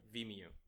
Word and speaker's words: Vimeo: Vimeo. – Vimeo.